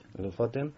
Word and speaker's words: Lofoten: Lofoten, ¿no? – Lofoten,